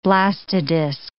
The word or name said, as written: blastodisc